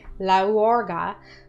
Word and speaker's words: katorga: It's important to note that the word laorga, the first syllable is stressed, and specifically had come to stand for a tsarist type of punishment, which summoned to mind idealistic revolution.